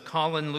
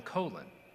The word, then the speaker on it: Colin: You know, it was interesting to hear that the family always called him Colin, but his pronunciation was changed to Colin while he was serving in the military, when a great military hero whose first name was pronounced Colin was there.